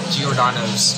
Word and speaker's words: Giordano's: We're at Giordano's. – Pretty sure that's how you pronounce it. – Yep. Yeah.